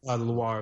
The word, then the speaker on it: Loire: Go over to, now I'm going to pronounce this incorrectly, but is it Luray or Loire? – Loire Valley.